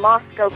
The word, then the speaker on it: Moscow: I have to give you a compliment because you pronounced the town name Moscow correctly. – Oh, good. – As Josh well knows, there are no cows in Idaho.